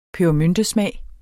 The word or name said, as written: pebermyntesmag